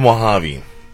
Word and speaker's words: Mojave: What do you think rhymes with Mojave? – Wasabi? – Hey! There you go.